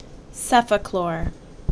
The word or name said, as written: Cefaclor